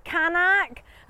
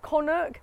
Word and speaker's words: Kanak: go, Kanak, and then I'd sort of embarrassingly put my hand up and then go, is it actually pronounced Konnuk? Because it's from the Sanskrit that's got lost in translation.